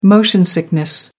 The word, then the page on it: motion sickness: American English